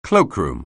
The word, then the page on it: cloakroom: anglais